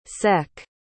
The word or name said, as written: seck